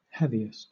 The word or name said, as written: heaviest